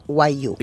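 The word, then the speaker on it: Wagyu: Se escribe W-A-G. ¿Cómo era, Eli? – G-Y-U. – Y-U. – Exactamente. – Y guayú. Se pronuncia guayú.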